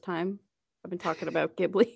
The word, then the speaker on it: Ghibli: And so I say Studio Ghibli because it feels softer. – That's how I say it too. Because I believe it's spelled G-H, right? – Right. – Ghibli, right? So to me, if I see G-H together, I don't think J. – Right. I think of a softer G.